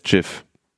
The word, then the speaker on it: GIF: 1987 äh, hat Steve Will Willite äh, das Format erfunden oder veröffentlicht und hat gesagt, es heißt GIF.